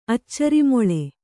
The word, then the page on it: ಅಚ್ಚರಿಮೊಳೆ: kannada